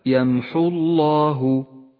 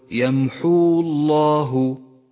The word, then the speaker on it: يمحو الله: سقطت الواو من اللفظ هنا لاجتماع الساكنين. اقرأ هكذا: يمحو الله، ولا تقل يمحو الله لأن الواو لا تلفظ.